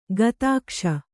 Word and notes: ಗುದಕಾಸ: gudakāsa

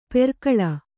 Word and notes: ಪೆರ್ಕಳ: Kannada

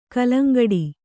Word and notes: ಗೆಲ್ಲಂಗುಡು: gelaŋguḍu

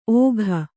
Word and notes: ಓಘ: ōgha; kannada

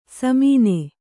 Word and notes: ಸಮಾನ: kannada

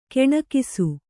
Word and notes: ಕೆಣಕಿಸು: keṇakisu

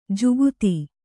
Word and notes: ಜಗತಿ: jagati

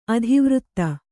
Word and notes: ಅತಿವೃತ್ತ: ativřtta

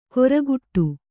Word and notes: ಕೊರಗುಟ್ಟು: koraguṭṭu

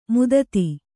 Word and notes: ಮದದ್: madad

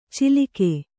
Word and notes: ಚಿಲಿಕೆ: cilike; Kannada

